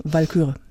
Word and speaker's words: Walküre: Walküre, nicht Wal. Betonung liegt auf Walküre, ja. Also auf den L dann hinterher, nicht das A.